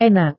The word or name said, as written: enak